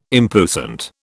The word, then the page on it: impuissant: British English